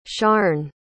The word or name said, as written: sharne